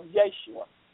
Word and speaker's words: Yeshua: do it in the name of Yeshua. In other words, the Aramaic pronunciation of his name.